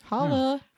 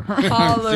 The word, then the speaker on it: holler: Make sure you really pronounce that R. – Holler. – Seriously. – It's a hard R.